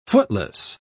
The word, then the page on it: footless: British English